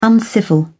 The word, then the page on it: uncivil: British English